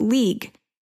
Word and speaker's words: Leagh: Now, I originally assumed they meant Lee, as it was spelled L-E-A-G-H. But according to Google, it would be pronounced League.